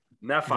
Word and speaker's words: Nephi: Nephi? – That's right. – Nephi, yeah, thank you.